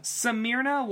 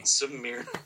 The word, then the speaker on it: Smyrna: Smyrna was the way to pronounce. Anyway. – Smyrna.